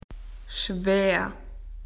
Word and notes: heavy: Australian English

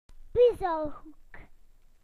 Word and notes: puzzle corner: British English

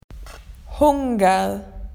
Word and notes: hungry: Australian English

